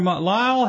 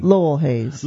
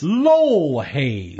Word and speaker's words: Lowell: And you've got a cool story from Lyle Hayes, or Lowell. How do you say his name? – Lowell Hayes. – Lowell Hayes. – I believe so. – Lyle Lowell Potato Patata. – L-O-W-E-L-L.